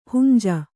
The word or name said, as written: ಹುಂಜಿ